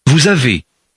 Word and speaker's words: vous: Vous avez. Vous avez. – What did you notice about number 1? The word vous has a disappearing S.